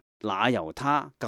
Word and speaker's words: 那由他: 那由他，传统读作罗由他，但是梵文是 Nayutta，所以应该读成那由他更为贴切的。